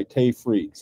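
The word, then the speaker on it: Tage Frid: but number one and number two by Tay Fried. So it's spelled TageFrid. Tay Fried teaches woodworking, book number one on joinery, and book number two on shaping, veneering, and finishing.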